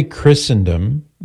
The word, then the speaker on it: Christendom: So we say Christendom with a silent T because some words in English have a silent T, like Christmas. – No. – You could say Christ Mass, but nobody says that.